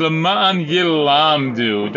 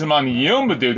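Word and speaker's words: Laman Yilamdu (למען ילמדו): Kari Bey, read it as Laman Yilamdu. Don't read it as Laman Yilmadu.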